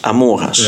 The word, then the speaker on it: Amoras: Amoras, ja. Oké, dus op de O ligt de klemtoon. Amoras, ja, Amoras.